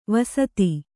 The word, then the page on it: ವಸ್ತಿ: vasti; Kannada